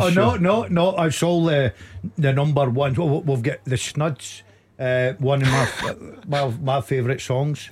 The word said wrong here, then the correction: Snuts: I mean, you, one of your favourite songs, you can't even pronounce The name of the band correctly. – The West Lothian Boys, four of them in the band. – The Snuts. – Snuts, yeah.